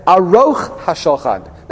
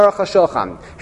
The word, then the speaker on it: Aruch HaShulchan: The Pasuk there says, Aruch HaShulchan, not Aruch HaShulchan, Aruch HaShulchan.